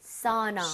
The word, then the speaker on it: sauna: 근데 일반적으로 전 추천하는 것이 AU 혹은 AW라는 스펠링이 보이잖아요. – 예. – 그러면 아, 우보다는 이런 느낌. – 사우나. – 사우나. – 사우나. – 느낌 맞죠? – 오, 괜찮죠? – 예. – 그래서 sounds like, 예를 들어서 법할 때도 AW죠? law. 이렇게 가듯이.